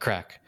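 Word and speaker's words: craic: They call that crack. It's a C-R-A-I-G, I think. Craig? It's not Craig, but it's like it's spelled Craig. Like something like that. They call it crack. – And it's with a hard C at the end? – Yes, crack? It is.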